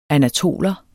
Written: anatoler

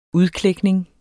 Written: udklækning

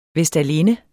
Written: vestalinde

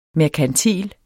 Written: merkantil

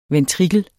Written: ventrikel

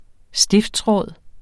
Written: stiftsråd

